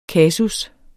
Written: kasus

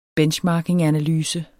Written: benchmarkinganalyse

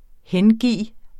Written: HENGIVET

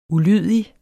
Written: ulydig

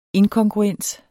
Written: inkongruens

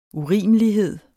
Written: urimelighed

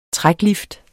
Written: træklift

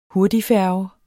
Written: hurtigfærge

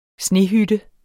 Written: snehytte

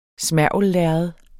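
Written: smergellærred

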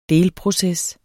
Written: delproces

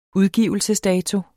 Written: udgivelsesdato

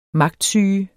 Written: magtsyge

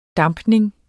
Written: dampning

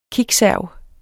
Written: kickserv

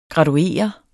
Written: graduere

